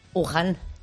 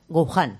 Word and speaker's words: Wuhan: Wuhan. – Wuhan. – Escrito W-U-H-A-N, sí. – Pero tenemos una gran maestra de chino, esa W dice que no se pronuncia, se queda como un Wuhan.